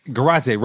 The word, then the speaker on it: Garate: Garate rhymes with karate. Or okay, as I like to say, karate.